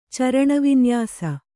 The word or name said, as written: ಚರಣವಿನ್ಯಾಸ